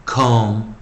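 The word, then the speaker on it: comb: 所以在 o 念长元的时候，b 不发音，o o com com com。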